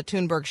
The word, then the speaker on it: Thunberg: Her last name's Thunberg. It looks like it's T-H-U-N-B-E-R-G, but since she's Swedish, Thunberg. – There you go. So we're going to talk about Greta Thunberg.